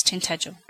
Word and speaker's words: Tintagel: Do you mean Tintagel, like the castle, King Arthur's castle? It's Tintagel.